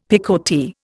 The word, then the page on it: picotee: Indian